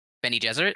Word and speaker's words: Bene Gesserit: Ben-E, like Benny, like the name. Gesserit.